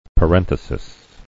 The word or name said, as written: parenthesis